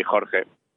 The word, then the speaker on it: Jorge: No, I always try to say Jorge. – That's beautiful. – My name's how you say it in Spanish, really.